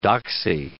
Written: doxy

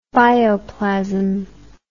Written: bioplasmic

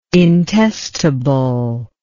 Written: intestable

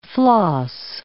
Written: floss

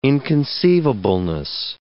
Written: inconceivableness

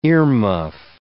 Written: earmuff

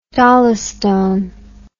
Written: dolostone

